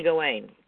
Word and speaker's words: Gawain: Finsterly Gawain. Gawain. Gawain like the night Gawain of the Round Table.